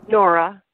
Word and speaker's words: Noura: And it's spelled N-O-U-R-A. That's Nora, correct? Because sometimes I've heard her pronounced Nora. – Yes. – Is it Nora or Nora? – Nora. It's pronunciation is the same, it's just a slightly different spelling, yes.